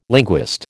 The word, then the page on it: linguist: American English